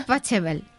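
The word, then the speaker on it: Pachelbel: de Pachabel. Que Es, super, es, que como tú lo has pronunciado, eh, no sé. Sí, aquí la, gente lo dice Pachabel.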